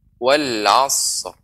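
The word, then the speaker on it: wal asr (العصر): Maka tidak boleh wal langsung disambung wal asr. Tidak boleh. Akan tapi berikan slot ya haknya baru kita tambahkan ro di ujungnya. Seperti pengucapan wal asr. Wal asr.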